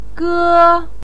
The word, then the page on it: ge: 1